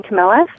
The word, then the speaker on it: Camilla: And vanilla just happens to rhyme with my name, Camilla, so I think you might be running with that idea, too.